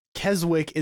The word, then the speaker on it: Keswick: As in spelt K-E-S-W-I-C-K. And Keswick is not how you pronounce it. – It's Keswick.